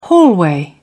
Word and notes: hallway: British English

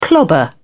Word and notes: clobber: British English

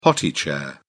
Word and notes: potty chair: British English

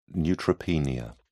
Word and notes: neutropenia: British English

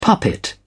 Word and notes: puppet: British English

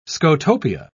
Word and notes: scotopia: British English

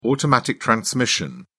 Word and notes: automatic transmission: British English

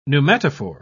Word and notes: pneumatophore: British English

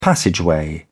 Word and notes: passageway: British English

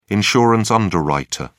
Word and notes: insurance underwriter: British English

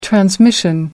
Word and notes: transmission: American English

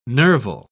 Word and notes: nerval: British English

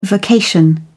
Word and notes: vocation: British English

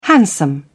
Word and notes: handsome: British English

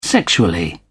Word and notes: sexually: British English

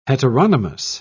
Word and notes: heteronymous: British English

